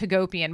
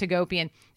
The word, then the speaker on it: Hagopian: My last name's Hagopian. Anybody out there knows that when you have a last name that ends with I-A-N, it ends in Ian, if it rhymes with the word Armenian, it is an Armenian last name.